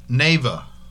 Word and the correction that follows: Nevaeh: Nevaeh versus Kimberly is next.